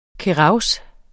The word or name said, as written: kehraus